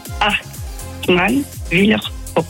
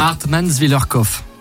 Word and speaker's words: Hartmannswillerkopf: Hartmannswillerkopf. – Voilà, c'est pas compliqué en fait. – Hartmannswillerkopf.